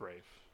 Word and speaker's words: Ralph: It's just Ralph. It's just pronounced Rafe.